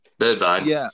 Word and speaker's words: Bergwijn: Bergwijn. – Bergwijn. Apparently, you can pronounce it two different ways. There's the Dutch way and the English way.